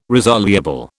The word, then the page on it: resoluble: British English